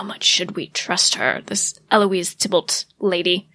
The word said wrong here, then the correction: Thibault: Yes, or as Tatiana says, Thibault. – You're correct. I said it wrong. I didn't say it the French way.